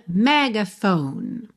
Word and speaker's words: megaphone: Megaphone não pronuncia o E final, tá bem? A sílaba tônica é a primeira, mas a gente acaba tendo assim uma semitônica, né? Um outro ponto de apoio aí no meio.